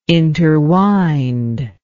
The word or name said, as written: interwind